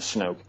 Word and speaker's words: Snook: And the other person is a Napa County deputy sheriff named Hal Snoke, you could pronounce it Snook. It's spelled S-N-O-O-K. As far as I know, the correct pronunciation is Snoke.